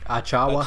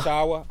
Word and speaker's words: Achiuwa: Precious, how you pronounce it? Achiuwa. – Achiuwa.